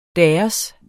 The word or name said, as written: deres